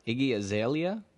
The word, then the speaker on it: Iggy Azalea: Number five, Iggy Azalea.